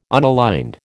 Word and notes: unaligned: American English

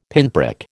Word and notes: pinprick: American English

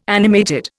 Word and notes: animated: Indian

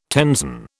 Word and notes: tenzon: British English

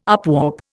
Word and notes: upwarp: Indian

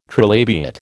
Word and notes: trilabiate: American English

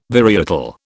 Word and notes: varietal: British English

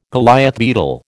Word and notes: Goliath beetle: American English

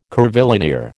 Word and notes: curvilinear: American English

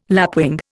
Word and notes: lapwing: Indian

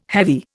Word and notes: heavy: Indian

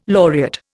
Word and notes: laureate: Indian

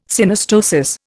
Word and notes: Synostosis: Indian

